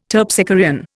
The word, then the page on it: Terpsichorean: Indian